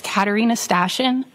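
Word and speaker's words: Katarina Stashyn: My name is Katarina Stashen. First name is spelled K-A-T-A-R-I-N-A. Last name is spelled S-T-A-S-H-Y-N.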